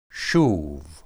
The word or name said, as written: shub